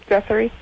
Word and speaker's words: Guthrie: Guthrie.